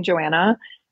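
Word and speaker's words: Johanna: It's my first and last name, Joanna. As Megan said, that's that sneaky little silent H, JOHANNAVOSS, like the water.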